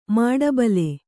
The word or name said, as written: ಮಿಡಿಬಿಲ್